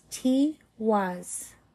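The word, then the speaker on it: Tiwaz: Pronunciation is T was.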